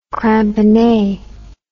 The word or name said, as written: cramponnee